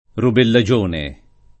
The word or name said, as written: rubellagione